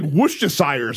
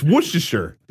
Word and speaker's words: Worcestershire: She's like Worcestershire sauce. It's Worcestershire.